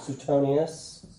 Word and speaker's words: Suetonius: Suetonius.